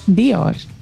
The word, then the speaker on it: Dior: Uh, jā, bet uh, pēc izrunas būtu jubu, burtam jābūt bez īburta. Diors, tā viņu izrunā. – Kristiāna Dior, Dior. taču viņa raksta. – Jā, bet izrunā Dior.